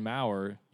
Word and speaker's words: Maurer: So as long as everything's okay with Brian Maurer, you can wait. You could go through the early signing period. – So the first R is silent? – Yes, it is.